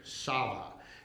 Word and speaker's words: Tsaba: Jehovah Saba, and it's spelled T-S-A-B-A, but it's pronounced S-A-V-A, Saba.